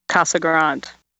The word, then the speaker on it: Casa Grande: Casa Grande.